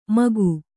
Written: ಮಕಿ

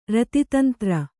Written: ರತಿತಂತ್ರ

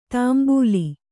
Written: ತಾಂಬೂಲಿ

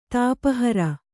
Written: ತಾಪಹರ

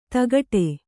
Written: ಧಕ್ಕಡಿ

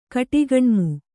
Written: ಕಟಿಗಣ್ಮು

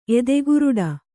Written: ಎದೆಗುರುಡ

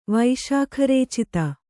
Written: ವೈಶಾಖರೇಚಿತ

